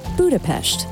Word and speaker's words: Budapest: When you visit, you're likely to hear the city name pronounced Budapest by the Hungarian locals. But the pronunciation is widely accepted without the sh sound, especially by visitors.